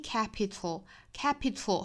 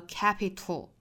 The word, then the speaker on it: capital: 好，那么后面 identity capital 后面的这个音节同样是一个舌边爆破，跟我们前天的早读里面的 little 是一样的发音方式，舌尖去抵上齿龈之后爆破送气的时候呢，舌头两边会有气流通过 little 和 capital